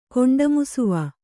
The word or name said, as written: ಕೊಂಡಮುಸುವ